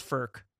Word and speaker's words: Frk: I think it's I think it's pronounced Firk.